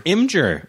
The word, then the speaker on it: Imgur: Like Imgur, is that how you say it? That happened the other day. – Imgur.